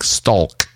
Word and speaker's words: stalk: Lately, I'm hearing people use the L word, like a word with a silent L in it, and they're actually pronouncing the L in it, like stalk. Oh, that guy's stalking him. And when I walk to work, I'm like, why are you pronouncing that L? It's silent.